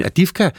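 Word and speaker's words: Avdiivka: Og hvis du vil høre mere om Mathilde Kimers besøg i byen Adivka, ja, sådan udtales det, så kan du lytte til dagens afsnit af Genstart i DR Lyden appen, hvor du jo også ellers kan finde din podcast.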